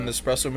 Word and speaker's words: Nespresso: Lack of love for the Nespresso machine. – Did you say that right? Yeah, lack of love for the Nespresso machine.